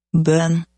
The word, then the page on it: Bøhn: Norwegian